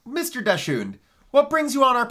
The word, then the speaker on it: Dachshund: It's spelled like the dog Dachshund. – Yes, it is. – And you pronounce it how? – Dachshund. – Dachshund? – Dachshund. – This is the family pronunciation.